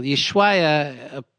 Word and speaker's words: Ushuaia: Yeah, Ushuaia.